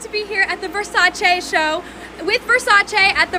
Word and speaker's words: Versace: He said Versace is the Italian pronunciation.